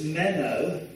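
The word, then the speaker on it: meno (μένω): The word continued is used, the Greek word is meno, M-E-N-O, we would spell it in English.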